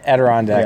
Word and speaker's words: Adirondack: You went on to play in the AHL with the Philadelphia Flyers AHL team, Adirondack. Is that how you say it? – Yeah, Adirondack. Yeah, it's a tough word.